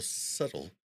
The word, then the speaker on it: subtle: And for anybody who is a smooth braid like me, the way you spell subtle is S-U-B-T-L-E. Just letting you guys know, it doesn't sound like it's spelled.